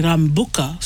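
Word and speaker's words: Rabuka: And lucky I already know the Prime Minister, Sitsiviri Rambuka. So, yes. But the way it's spelled does not have the M.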